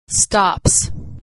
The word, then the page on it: stops: ending s